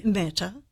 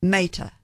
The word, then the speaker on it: Mehta: Mehta. – Mehta.